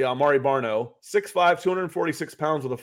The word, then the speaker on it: Amare Barno: Um, but uh, um, is it Amare Barno? from Texas, uh, Virginia from Tech. – Virginia Tech. Did I pronounce his name right? I think so.